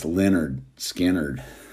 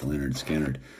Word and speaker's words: Lynyrd Skynyrd: Pronounced Lynyrd Skynyrd. It's pronounced Lynyrd Skynyrd.